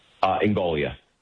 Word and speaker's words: Ingoglia: Am I pronouncing the last name correctly, sir? – Ingoglia. – Oh, the second G is silent.